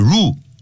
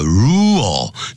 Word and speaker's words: rule: The new rule, 这个新的规定 ,rule, 不要念成 rule, 啊叫 rule,